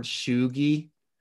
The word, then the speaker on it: cheugy: but have you heard the word shoogie? – Shoogie? No. What is that? – Shoogie. All right. So shoogie. Google it. It's spelled C-H-E-U-G-Y. Shoogie. – C-H-E-U-G-Y.